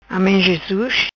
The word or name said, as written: Amen Jesus